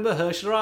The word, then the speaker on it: Mahershala: It took me 5 minutes this morning of listening to it on repeat to go Mahershala. Mahershi. Mahushu. Mahershala. Mahershala. Mahershala. See, it still happens sometimes. Mahershala Ali.